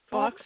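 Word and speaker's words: Fawkes: And how do you say the phoenix's name? Is it Fox? – Fox, yeah. Fox. That's how I pronounce Fox.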